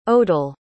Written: odel

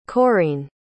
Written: Coreene

